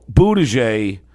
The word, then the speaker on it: Buttigieg: How do you say his name? – Buttigieg. – Oh, Buttigieg. – I think that's how you say it.